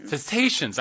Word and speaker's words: cetaceans: Cetaceans.